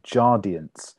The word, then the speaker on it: Jardiance: So this week, we both text each other simultaneously about a drug called Jardiance. That's how you pronounce it.